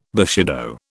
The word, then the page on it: bushido: British English